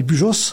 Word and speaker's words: Pujols: Alors Pujols avec un S à la fin. – Oui, c'est Pujols, ça s'écrit, voilà, nous on dit Pujols en occitan.